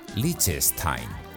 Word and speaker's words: Liechtenstein: Liechtenstein se pronuncia en realidad Liechtenstein.